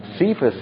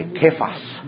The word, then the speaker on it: Kephas: Now, Cephas is the anglicized pronunciation. The Aramaic is actually Kephas. Kephas. So where you see Cephas in your New Testament, actually the original was Kephas.